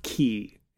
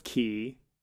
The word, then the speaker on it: quay: I think it's pronounced key, and it always bothers me because it's spelled quay.